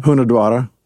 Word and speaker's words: Hunedoara: And that, how, how do you say that again? Hunedoara. Huna Draw. – You got to yeah, roll that R.